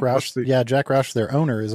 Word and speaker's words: Roush: Actually, Roush is the way you pronounce that.